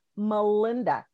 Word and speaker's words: Melinda: Well, I tell you what, I'm Melinda Cooper, and it's Melinda. Do not spell it M-A. The name is M-E-L-I-N-D-A.